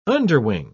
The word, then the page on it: underwing: British English